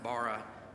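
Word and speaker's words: bara (בָּרָא): In the Hebrew, it's the verb pronounced bara, but it's the same Hebrew word used in Genesis 1:1.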